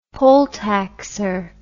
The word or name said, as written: poll taxer